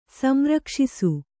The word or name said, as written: ಸಂರಕ್ಷಿಸು